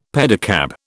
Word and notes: pedicab: British English

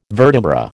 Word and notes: vertebra: American English

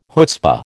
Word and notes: chutzpah: American English

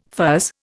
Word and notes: fuzz: Indian

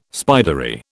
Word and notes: spidery: British English

